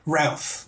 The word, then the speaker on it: Routh: It's Routh, by the way, I think. – Oh, it's Routh. – Routh. – Routh, okay. – Rhymes with South.